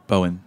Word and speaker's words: Bowien: Danny Bowine's place. Is that the right way to pronounce Danny? – Bowen. – Bowen.